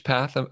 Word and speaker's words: PATH: It's P/ATH, PATH, I'm assuming is how you say it.